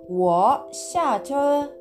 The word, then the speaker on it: 我下车: これは、我下車。